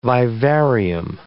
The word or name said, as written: vivarium